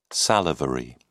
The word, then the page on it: salivary: British English